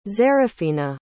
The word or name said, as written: xeraphina